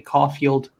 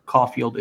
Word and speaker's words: Caulfield: You can find me on Twitter and most other social media at Call Me Caulfield. Caulfield is spelled CAULFIELD.